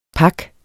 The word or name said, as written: PAKS